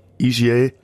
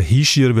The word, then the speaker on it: Hischier: Ähm, richtig muss man, glaube ich, sagen Hischier, weil er ein Oberwalliser okay, ist.